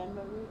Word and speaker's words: Anne Marie: Anne Marie Lenay.